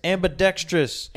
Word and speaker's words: ambidextrous: No, it's not trius. It's ambidextrous.